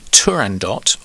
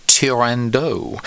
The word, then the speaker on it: Turandot: Now, there's a bit of a disagreement in terms of how one should pronounce this. Is it Turandot, or, if you think the leading character was French, Turandot?